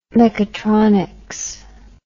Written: mechatronics

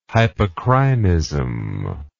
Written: hypocrinism

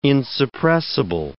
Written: insuppressible

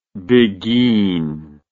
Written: biguine

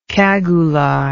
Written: cagoulard

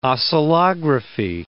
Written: oscillography

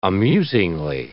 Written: amusingly

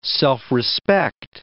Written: self-respect

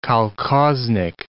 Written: kolkhoznik

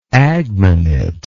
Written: agminate

